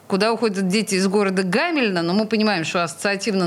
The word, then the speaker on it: Гамельна: И в его рамках Петербургу был показан любопытный фильм, который называется «Куда уходят дети из города Гамельна». Правильно ли я ударение ставлю? – Да, все верно.